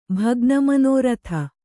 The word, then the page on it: ಭಗ್ನಮನೋರಥೆ: bhagna manōrathe